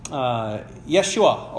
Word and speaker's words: Yeshua: Jesus is the Greek version of a Hebrew name, Yeshua or Joshua, as we call it. That would be what Jesus' name pronounced in Hebrew would be.